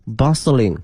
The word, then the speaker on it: bustling: 同学们注意一下这个 t 呢是不发音的 ,bustling,